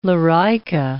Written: lorica

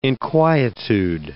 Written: inquietude

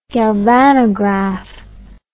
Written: galvanograph

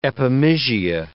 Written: epimysia

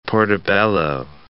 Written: portobello

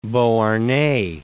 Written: Beauharnais, de

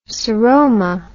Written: seroma

